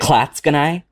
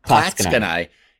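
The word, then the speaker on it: Clatskanie: Klatskanai. – Klatskanai.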